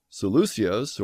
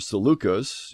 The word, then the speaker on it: Seleucus: And when you find one of Ptolemy's generals, Seleucus, or Seleucus, you can pronounce his name either way,